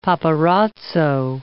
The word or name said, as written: paparazzo